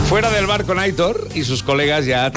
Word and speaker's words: Aitor: que dice, por favor, que Aitor se pronuncia como se lee. – Sí, no, Aitor. – Aitor, dice la acentuación es en la O. – En la O. Claro. Aitor.